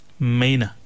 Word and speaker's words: Mainour: We've given the correct answer, and the word is, it's pronounced as Mena. But it's spelled very much differently. It's spelled M-A-I-N-O-U-R.